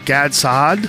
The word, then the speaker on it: Gad Saad: It's uh, Gad Saad. Did I say it right? Yep.